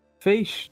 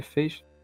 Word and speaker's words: Fez: Bom, Fez, ou Fez, eu acho que a pronúncia correta realmente é Fez, é um jogo desenvolvido pela Polytron Corporation e ele foi publicado pela Trapdoor.